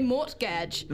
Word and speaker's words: mortgage: And talented Jess Fo, who yesterday said, "Hey, why don't we pronounce the T in mortgage?" And I said, "I think about this all the time. And I always want to say mortgage.